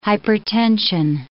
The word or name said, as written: hypertension